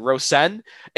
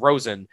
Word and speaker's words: Rosén: I think that's at this point how we pronounce it, Rosén. And I interviewed him last night at WGR and I said Rosen.